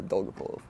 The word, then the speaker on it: Dolgopolov: Oh, Dolgopolov. – Yeah. – How do you pronounce it? – Yeah, Dolgopolov.